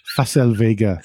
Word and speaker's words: Facel Vega: I am going to uh, select the Fecal Vega HK500. – Fecal. – Fas. Oh, Facel Vega.